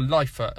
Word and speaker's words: Lifa: And the album's called Lifa, or Lifa. It's spelled L-I-F-A, and Heilung is spelled H-E-I-L-U-N-G.